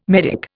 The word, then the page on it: medick: Indian